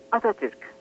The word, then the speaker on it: Atatürk: Atatürk.